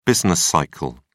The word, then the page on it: business cycle: British English